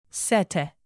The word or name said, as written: cete